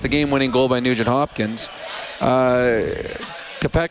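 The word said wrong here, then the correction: Konecny: how do you say his name? Number 11? – That was the power play. – Yeah, well, yeah, what's is it? – Konechny.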